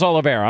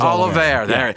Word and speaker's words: Oliveira: How do you pronounce that name? – Charles, I'm blanking on it. – It's Olivier. – Charles Oliveira. I'm sorry. Charles Oliveira.